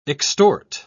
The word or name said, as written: extort